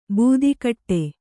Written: ಬೆದೆಗೂಡು

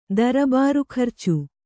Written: ದರಬಾರುಖರ್ಚು

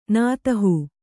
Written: ನಾತಹೂ

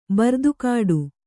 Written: ಬರ್ದುಗೆಡು

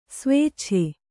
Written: ಸ್ವೇಚ್ಛೆ

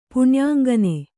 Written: ಪಣ್ಯಾಂಗನೆ

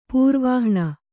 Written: ಪೂರ್ವಾಹ್ಣ

